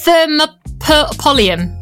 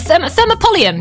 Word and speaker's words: thermopolium: So it's called a, I'm going to try and say this right, a thermopolium. A thermopolium. Yeah. – Nailed it.